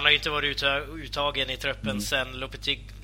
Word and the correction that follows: Lopetegui: Lopetegui eh, tog över, eller så han har ju varit petad sedan EM eh, e egentligen, Juan Fran.